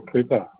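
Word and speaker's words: Couper: My name's Gabriel Cooper.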